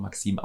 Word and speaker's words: MKSM: A und I fällt weg und jetzt haben wir MKSM, was natürlich aber nach wie vor Maxim ausgesprochen wird.